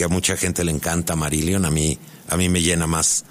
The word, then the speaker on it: Marillion: Marillion.